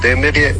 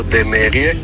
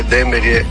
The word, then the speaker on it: Demerje: Ime naselja Demerje se izgovara kao Demerje, ne kao Demerje. Pa onda tako i sa naplatnim postajama, dakle naplatne postaje Demerje, ne Demerje. Pozdrav iz Demerja.